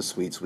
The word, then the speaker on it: suite: It also has the same pronunciation as the sweets we eat without an S at the end. Sweet.